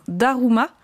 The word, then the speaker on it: Daruma: Ça s'appelle un Daruma, je prononce bien.